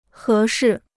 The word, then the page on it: 合适: hé shì